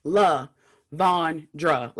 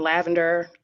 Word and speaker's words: LaVondra: You know, the first part is definitely, like, it's even spelled like La Von Dra.